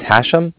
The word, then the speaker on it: Hashem: It's Hashem. – Spell it. – H-A-S. H-E-M, as in mother at the end.